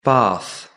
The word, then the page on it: bath: British English